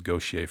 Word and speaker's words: Gauthier: I didn't realize her name was Gautier for a long time because it's spelled Gautier.